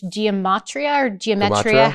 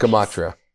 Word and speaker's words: Gematria: and then you calculate that using Jewish geometria, however you say that, yeah. Always pronounce it wrong. – Always. Gematria.